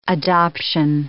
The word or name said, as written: adoption